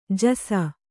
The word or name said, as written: ಜೈಸು